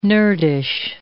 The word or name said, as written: nerdish